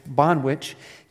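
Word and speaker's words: Banh Wich: Okay, for the rest of you, you spell it B-A-N-H, which, W-I-C-H. So it's like banh mi, which is a Vietnamese sandwich put together with sandwich, Bonwich Cafe.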